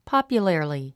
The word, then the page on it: popularly: pάpjulə r li; ポォピュラリィ